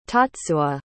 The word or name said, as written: tatsuo